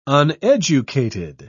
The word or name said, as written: uneducated